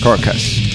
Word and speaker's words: Carcass: Me gusta mucho decir carcas, pero no carcas, porque acá como decimos carcas, pero ¿cómo se dice en inglés? Carcass, carcas.